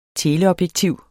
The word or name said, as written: teleobjektiv